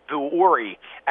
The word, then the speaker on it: Vuori: Vuori, as it's pronounced, so in that athleisure space.